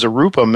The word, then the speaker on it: Avrupa: So the first one is Arupa Minerals, and I believe that's spelled A-V-R-U-P-A Minerals. Trades in Toronto under the symbol A-V-U.